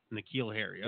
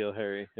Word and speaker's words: N'Keal Harry: So then now the second pick in our Dynasty draft went to, how do you pronounce his name? – N'Keal Harry? – N'Keal Harry, okay. – Yeah, N'Keal Harry.